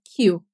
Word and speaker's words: queue: Olha a pronúncia da palavra, queue. Para você soletrar ela, é Q-U-E-U-E.